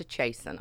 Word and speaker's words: chasten: C-H-A-S-T-E-N. Chasing. – Chase. So the chasing. T is silent? – The T is silent.